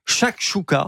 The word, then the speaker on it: chakchouka: Chakchouka.